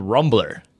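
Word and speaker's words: Rumblr: It's on beta trial called Rumbler. And it's spelled like Tumblr, so R-U-M-B-L-R. I guess, you don't need the E.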